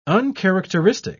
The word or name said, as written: uncharacteristic